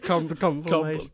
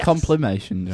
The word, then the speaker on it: compilation: Compilation. – Compilation. – Don't mean complimation. – Yes, I mean complimation.